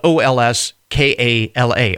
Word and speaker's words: Olskala: According to the Greeks, they say that the term comes from the phrase Olskala, uh, which is spelled O L S K A L A, Olskala, which means everything is good or all right.